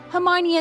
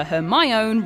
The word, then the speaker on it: Hermione: He calls her Hermione rather than Hermione. So Hermione decides to teach him the correct way.